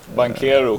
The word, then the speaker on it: Banchero: Banquero. Banquero. Banchero, alltså, okay. Stefan tror att det är Banchero, men han är ju förstås har ju något italienskt påbrå och då uttalas CHK.